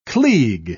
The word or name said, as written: klieg